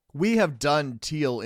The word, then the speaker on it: Thiel: That is definitely how you pronounce Teal. Here's how you spell Teal. T-H-I-E-L. That's how you spell Teal. – Exactly how it sounds.